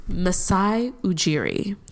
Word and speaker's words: Masai Ujiri: Masai Ujiri.